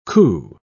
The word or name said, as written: coup